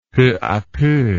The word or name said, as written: peu a peu